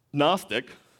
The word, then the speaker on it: Gnostic: But Gnostic, it's spelled with a G. It doesn't sound like it, but G-N-O-S-T-I-C, Gnostic.